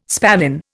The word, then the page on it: spavin: Indian